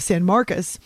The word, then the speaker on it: San Marcos: So I went south around Austin and was making a stop in the area of San Marcos. That's how they say it locally. I would say San Marcos. My husband corrected me. Around here, people say San Marcos.